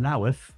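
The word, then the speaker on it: Louth: My first gig was in the Greyhound in Lowth. You've got to pronounce it like that, apparently.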